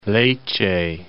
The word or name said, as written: Lecce